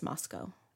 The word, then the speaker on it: Moscow: I just learned that it's pronounced Moscow. Oh. Like rhymes with Costco.